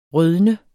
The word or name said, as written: rødne